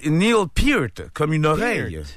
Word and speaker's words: Peart: A, Neil Peart, comme une pearl, comme une perle. – OK, Neil Peart. Neil Peart.